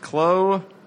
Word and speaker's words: Klobuchar: So Amy, last name previously unable for us to pronounce, he says it's pronounced Klobuchar. – Klobuchar? – Yes. You would say it much faster, I think, in normal English speak, but the way he typed it, it is Klo-Bu-Char. – Well, that's the pronunciation.